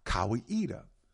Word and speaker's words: Kawaida: It is a Swahili word meaning tradition or reason, pronounced Kawaida.